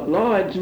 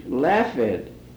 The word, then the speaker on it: Laughead: Now, Lawhead's name is spelled L-A-U-G-H-E-A-D, Laughhead, but is pronounced Lawhead, L-A-W-E-D.